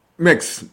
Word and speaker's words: MEXC: MEXC, ¿cómo se pronunciará? MEX. – MEX, bien, bien, mi amigo.